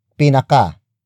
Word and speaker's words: pinaka: The first way of creating a superlative adjective in the Tagalog language is by adding the prefix pinaka. Pinaka. Pinaka. Pinaka is spelled as P-I-N-A-K-A.